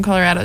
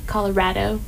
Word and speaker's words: Colorado: Also, shout out to everyone who pronounces the name of our state properly. – Yeah, Colorado. – Not Colorado. All these stinking East Coasters stress me out and make fun of me for pronouncing my own state's name as Colorado. But Frank Ocean says Colorado, so final verdict. – Also, everyone in Colorado says Colorado, except for Kira, who says it wrong, and she's from there, and I don't understand it.